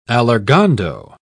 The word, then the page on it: allargando: British English